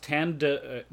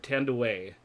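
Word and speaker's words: Thandiwe: Yeah, it's pronounced Tande. The D is really hard. Tandeway. So.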